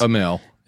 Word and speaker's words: Amell: Anyway, a couple of cousins, Robbie Amell, is that how you pronounce it? You know all these guys. – Amell, yeah.